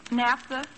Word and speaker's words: naphtha: Now, how would you correctly pronounce N-A-P-H-T-H-A? – Naphtha. – How's that? – Naphtha. – Well, spell it like you'd pronounce it now for me. – N-A-F-T-H-A. – Naphtha is right.